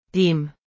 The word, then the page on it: taim: arabic